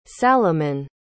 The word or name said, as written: salamon